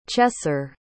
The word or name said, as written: chesser